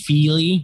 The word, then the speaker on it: Fili: No, I said it's definitely pronounced Fili, but okay. – What did I say? – Fili. – Oh yeah, but no. – Like a fillet of fish.